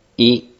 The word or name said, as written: i